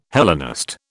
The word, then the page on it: Hellenist: British English